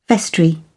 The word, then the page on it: vestry: British English